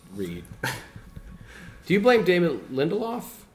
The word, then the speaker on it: Lindelof: Lindelof.